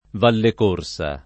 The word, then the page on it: Vallecorsa: Italian